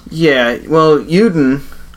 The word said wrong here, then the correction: Udon: I believe I'm pronouncing it right, Yuden, kind of like the noodle. – Udon? – Yeah.